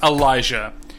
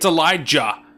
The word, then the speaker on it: Elijah: It is how Bruce Willis fucking says Elijah. He keeps putting a J, like a hard J. It's Elijah.